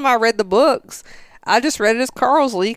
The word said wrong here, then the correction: Carlisle: because that's how she pronounced it. And the name is Carlisle.